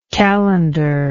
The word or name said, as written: calendaric